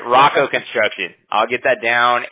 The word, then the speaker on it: Roco: R-O-C-O. – R-O-C-O. Okay, perfect. Is it Roco or Rocco? How do you say it? – We say Rocco. If Rocco. – You say Rocco, I would understand too.